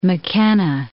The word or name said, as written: McKenna